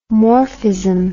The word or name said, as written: morphism